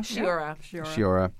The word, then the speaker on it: Sciorra: Sciorra.